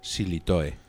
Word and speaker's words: Sillitoe: Alan Sillitoe. – Sí, como Alain Delon. Perfecto, porque es una pronunciación entre francesa e inglesa que es muy internacionalista.